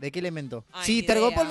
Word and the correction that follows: Telgopor: no sé cómo se dice. ¿Cómo es Telgopor? – Telgopor, tela gomosa porosa.